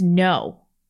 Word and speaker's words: Nguyen: His last name's No. – That's how it's pronounced? – It's Nguyen. It's Nguyen. Okay.